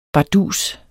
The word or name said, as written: bardus